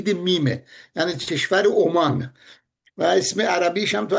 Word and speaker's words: عمان: ولی این درسته، کشور تلفظ کشور عمان، عمان بدون تشدید.